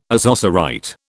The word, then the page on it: ozocerite: British English